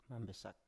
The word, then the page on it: mambesak: Biak